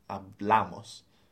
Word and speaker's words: hablamos: We've got nosotros hablamos. So it's on the second syllable there. – Yeah. The accent is the second.